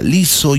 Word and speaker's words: Lizzo: L-I-Z-Z-O, así se escribe, así se pronuncia. Lizo Yango.